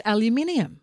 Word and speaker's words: aluminium: They drop the middle I in aluminium. – Yeah. – Americans do. – They get it wrong. It's aluminium.